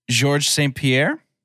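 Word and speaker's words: Georges St-Pierre: His first name is Georges. I know I'm saying that wrong. – Yeah. I guess it's probably like George. – It is George St. Pierre.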